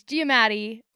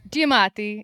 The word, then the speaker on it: Giammattei: It's Giammattei or Giammattei, if you want to go like full Italian.